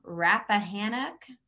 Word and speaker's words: Rappahannock: going to say it wrong. Rappahannock. – Correct.